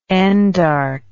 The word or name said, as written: endarchy